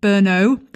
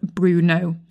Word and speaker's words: Brno: Anyway, my point being, I've been mispronouncing Brno. That's the correct pronunciation. In this episode, I keep referring to it as Bruno. So Brno is the correct way to say this city name. B-R-N-O.